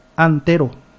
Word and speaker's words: Antero: Pecha hoy en el día, concejal municipal de la ciudad de San Japuita, Antero Ruiz. Acento en la E.